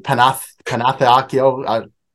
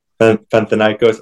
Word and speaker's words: Panathinaikos: Panathinaikos. – Panathinaikos.